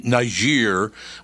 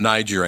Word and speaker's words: Niger: Niger, which it's not Niger anymore, it's now Niger. Because apparently somebody decided that's the way it's actually pronounced in French or something.